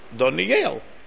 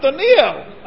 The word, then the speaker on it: Doniel: Doniel, because the accent's on the last syllable. Shkoyachari for that trick. It's not a trick. Anyway, Doniel. So then I come home and I tell my family, it's not Doniel.